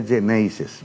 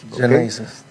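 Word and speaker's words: Xeneize: Y que se pronunciaría Ceneices. – Ceneices. – ¿Okay? En un dialecto genovés.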